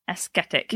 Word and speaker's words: ascetic: They pronounced identically. Ascetic. – No, one's got a C, one's got a T. Ascetic. – I know, but I wasn't sure if it was silent C. – Ascetic. – Like omniscient. Aesthetic. Ascetic. I think it's ascetic. – Okay, ascetic and aesthetic.